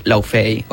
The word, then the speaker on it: Laufey: No sé si la coneixeu, però una cançó es va fer molt famosa, que es diu Laufey, o Luffy es pronuncia, que la cançó de From the Start.